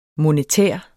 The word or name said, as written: monetær